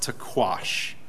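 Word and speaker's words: taqsh: It's T-A-Q-S-H. And I'm told that it's pronounced taquash. Taquash. That's all one word.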